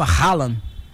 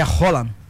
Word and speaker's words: Haaland: Até eu mesmo falava Haaland. – Haaland. – Mas é Roland. – Roland. – É? Não é, não é, é Roland. É? Ele. A FIFA disponibilizou. Não, mas não. Mas a FIFA disponibilizou. Né, os vídeos das pronúncias, né? E ele fala Roland.